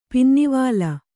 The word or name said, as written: ಪಿನ್ನಿವಾಲ